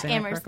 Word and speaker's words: Amherst: Yeah, Amherst. The H is silent. It's A-M-H-E-R-S-T.